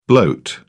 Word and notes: bloat: British English

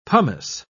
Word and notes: pomace: British English

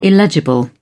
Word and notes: illegible: British English

